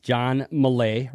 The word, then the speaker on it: John Millea: But before we get to our very, very special guest, it's John Millea. Rhymes with hay, by the way.